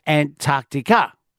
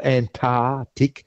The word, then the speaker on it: Antarctic: And I hope you have fun at the Antarctic or in Antarctica. – And thank you for pronouncing the C. So it's not Antarctic, no, it's Antarctic. – That's right, Antarctic. – There's a little C there before the T.